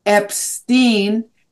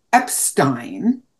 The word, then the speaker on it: Epstein: And I said Epstein, and he immediately corrected me. It is Epstein.